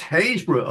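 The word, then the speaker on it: Happisburgh: Happisburg?" And the guy says, "Where?" And you say, "How do you pronounce this place?" And the fellow says, "It's Haysborough, of course."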